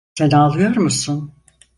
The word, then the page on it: Sen: turkce